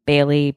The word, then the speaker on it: Bailey: You should follow me at Kaytlin Bailey. My parents spelled my name wrong. It is Kaytlin. Bailey, B-A-I-L-E-Y.